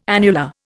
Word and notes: annular: Indian

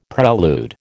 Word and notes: prelude: American English

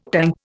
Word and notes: tanka: Indian

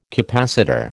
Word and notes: capacitor: American English

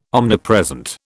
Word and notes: omnipresent: British English

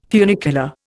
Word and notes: funicular: Indian

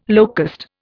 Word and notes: locust: Indian

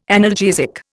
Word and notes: analgesic: Indian

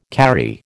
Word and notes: karri: American English

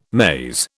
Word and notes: maize: British English

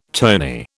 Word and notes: tony: British English

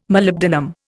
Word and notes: Molybdenum: Indian

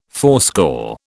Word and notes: fourscore: British English

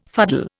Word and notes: fuddle: Indian

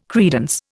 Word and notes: credence: Indian

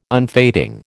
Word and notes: unfading: American English

